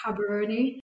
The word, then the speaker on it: Gaborone: So here the G is pronounced as a H sound. – Okay. So yes. It looks like it's spelt Gaborone. – Differently, yeah. – Yeah, Habaroni.